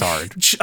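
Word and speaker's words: Xrd: It is XR'd. I hate to correct you. It is pronounced XR'd. – I choose not. – Based on the way that the man says it in the trailer.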